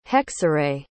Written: hexerei

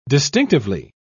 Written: distinctively

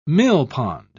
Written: millpond

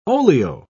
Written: oleo

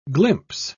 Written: glimpse

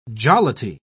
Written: jollity

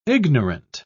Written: ignorant

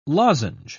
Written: lozenge

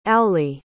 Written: auley

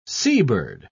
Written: seabird

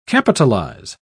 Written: capitalize

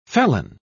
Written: felon

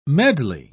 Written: medley

